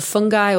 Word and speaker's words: fungi: Sorry, I say fungi because it was the American pronunciation.